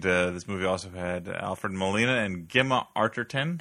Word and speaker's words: Molina: Alfred Molina.